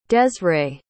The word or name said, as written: dezrae